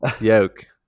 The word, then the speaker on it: yolk: Yolk. I think the L is silent.